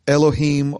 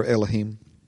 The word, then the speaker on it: Elohim: In the original language, it's the word Elohim or Elohim. People pronounce it different ways. I just prefer Elohim.